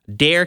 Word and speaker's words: Dare: The order was issued by the states in, pardon me if I don't pronounce this properly, Dare County for Hatteras Island.